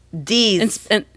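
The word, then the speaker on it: dix: How do you say 10? Dies? Yes? – Dies. – Dies in French, right? – Dies.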